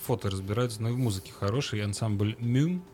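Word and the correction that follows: Múm: правильно я произношу? – Мум. – Мум.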